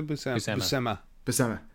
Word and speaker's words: Buscema: No, John Buscemi. Well, no, what's his name? This is a John Buscemi artist, right? – There's a Buscema. Sorry, yeah, I knew I was saying it wrong.